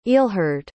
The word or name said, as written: ealhhard